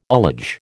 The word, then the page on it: ullage: American English